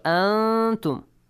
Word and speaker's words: أَنْتُمْ: So, أَنْتُمْ. Make sure the noon is hiddenly pronounced with a ghunna sound. This way. Not an. Don't pronounce the noon.